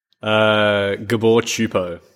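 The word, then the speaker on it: Gábor Csupó: Bridge of Terabithia, 2007, Gabor Sispo film. Was that good pronunciation? – Gabor Chupo. – Yes, not even close.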